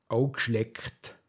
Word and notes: grinteg: Pinzgauer Mundart

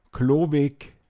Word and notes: Kåischlåog: Pinzgauer Mundart

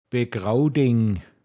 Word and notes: de Sunn ble(n)agetzt: Pinzgauer Mundart

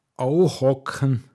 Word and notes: puschzln: Pinzgauer Mundart

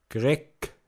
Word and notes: gogel: Pinzgauer Mundart

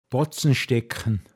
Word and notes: Griffeschåchtl: Pinzgauer Mundart

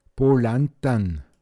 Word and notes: as Bett balantan: Pinzgauer Mundart

